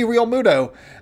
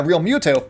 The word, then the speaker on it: Realmuto: JT Realmuto, uh, Realmuto, there we go. Kept saying it wrong. Uh, Realmuto he's going to be the guy that really does it.